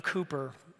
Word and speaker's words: Cowper: Many Christians know the name William Cooper. It looks like it's spelled Cowper, but William Cooper, who was a great, phenomenal, prolific hymn writer and poet, a deep soul.